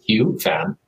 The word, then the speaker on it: Hieu Pham: So my name is Hieu Pham. The way that my family calls me, the way it's pronounced in Vietnamese is Hieu Pham.